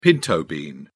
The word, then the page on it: pinto bean: British English